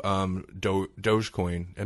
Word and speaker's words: Dogecoin: First, now, we don't have to get too much into Dogecoin. Am I pronouncing that right? – Yeah, you're pronouncing it right, yes.